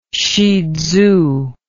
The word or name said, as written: shihtzu